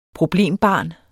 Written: problembarn

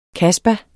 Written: kasba

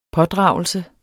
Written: pådragelse

